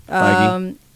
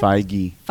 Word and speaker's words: Feige: Feige. – Yeah. What, how do you say his last name? – Feige.